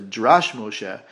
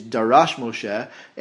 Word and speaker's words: Darash Moshe: I've often heard this Sefer or Moshe on the Parsha be referred to as Drash Moshe, but because this is in connection with this Pasuk, the correct pronunciation of the Sefer is Darash Moshe, after the phrase Darosh, Darash Moshe.